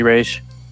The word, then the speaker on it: Ra's: And so, yeah, there's actually a pronunciation for it, and it's apparently Ra's.